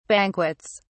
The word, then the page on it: banquets: British English